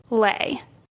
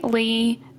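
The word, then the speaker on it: Le: One thing I want to say is her last name is spelled L-E, but when I was doing my research and watching some videos, it was pronounced Lay. So I might accidentally say Lee just from reading it, but I mean Lay.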